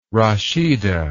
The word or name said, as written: Rashida